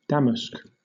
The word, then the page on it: damask: British English